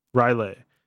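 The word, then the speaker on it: R'lyeh: He's influencing people from deep in sunken Raleigh. How do you say it? – Riley.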